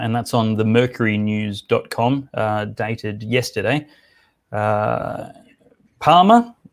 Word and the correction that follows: Palma: is Pal- Palma, maybe I should pronounce that.